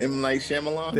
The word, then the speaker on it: M. Night Shyamalan: However you say his name, hey, butchered it. – M. Night Shyamalan? – There you go.